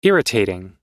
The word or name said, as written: irritating